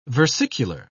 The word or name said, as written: versicular